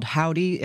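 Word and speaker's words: Hovvdy: We're going to start things off with a brand new entry to the charts this week from a band called Howdy. It's spelled Hovdi with two V's.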